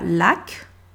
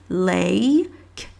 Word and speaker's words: lake: Pour qu'en fait on comprenne que le L-A-K-E, c'est pas lac, c'est un, un un A long, le, A long anglais qui est A, A, lake, comme abeille, abeille.